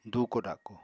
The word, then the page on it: dùuko-dako: apatani